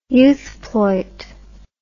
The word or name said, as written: youthploit